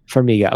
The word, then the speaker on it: Farmiga: I think I would Farmiga. – Know. Farmiga.